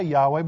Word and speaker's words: Yahweh: I say Yahweh because that is what's been identified by Hebrew scholars.